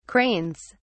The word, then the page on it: cranes: British English